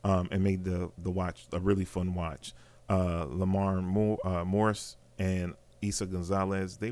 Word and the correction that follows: Lamorne: I know, I hope I'm pronouncing it correctly. It's Lamorne.